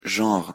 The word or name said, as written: genre